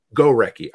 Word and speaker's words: Gorecki: And so is it Gorecki? Is that how I pronounce it? Or Gorecki. – Gorecki.